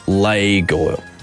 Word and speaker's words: Lego: Which is actually pronounced Lego. Lego. So anyone who says they're called Legos are absolutely correct. Lego is the incorrect pronunciation because it comes from Legot, L-E-G. Spelt like leg, pronounced like lay, and then got, G-O-D-T.